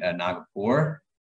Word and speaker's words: Nagpur: Nagpur.